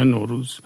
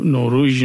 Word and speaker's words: نوروز: مثلا حتی در کردی که به روز میگن روژ به زبان خود کردی، ولی نوروز میگن نوروز، یعنی نوروژ نمیگن.